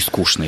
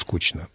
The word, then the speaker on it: скучно: И «скучно» еще, наверное. Да, да. Да. Ну, даже со словом «скучно» уже колебания. Mm-hmm. Уже колебания. И некоторые петербуржцы говорят «скучно».